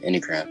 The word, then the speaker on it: Enneagram: Enneagram? – Enneagram.